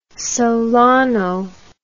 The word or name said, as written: solano